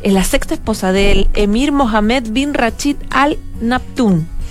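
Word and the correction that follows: Maktoum: Lo dije bien, Maktun. Exactamente.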